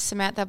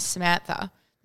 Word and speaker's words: Samantha: my name's Samantha, blah, blah, blah, and they'll go, thanks, Amanda. Like, why are you calling me Amanda? My name is Samantha. There's a S sound at the beginning.